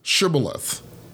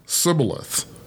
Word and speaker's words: shibboleth: The people from Gilead pronounced the word as shibboleth, beginning with the SH sound, like in shoe. But the people from Ephraim pronounced the word sibboleth, beginning with the S sound, like in so.